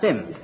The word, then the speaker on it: Simms: It's spelled S-I-M-M-S, but pronounced Sims.